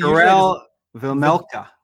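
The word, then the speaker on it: Karel Vejmelka: Karel Vemelka. – Vemelka. Yeah, I know. It's the Karel part that I always get wrong. Yeah. But anyways, Karel Vemelka.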